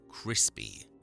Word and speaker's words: Crespi: I know what you're thinking too, did he say Crespi or Crispy? It's called The Crime of Dr. Crespi, but it sounds like The Crime of Dr. Crispy. Everyone in the film pronounces it Crispy, which does make the premise of the film a lot less terrifying.